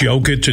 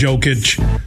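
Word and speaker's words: Jokić: The Joker, they all call him the Joker because everybody mispronounces his name the wrong. It's Jokic, it's Jokic.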